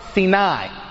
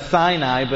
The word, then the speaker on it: Sinai: And they read Bahar Sinai. All right. In English, we would pronounce that Sinai, but in Hebrew, it's pronounced Sinai. So Bahar Sinai.